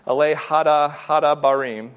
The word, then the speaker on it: Ele Hadabarim: And it's taken the first two words of the Hebrew, which are Ele Hadabarim. That's right. I think that's how you pronounce it. Ele Hadabarim. Yep.